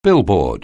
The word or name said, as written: billboard